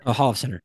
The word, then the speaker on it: Holofcener: Holofcener. – Holofcener.